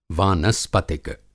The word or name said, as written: वानस्पतिक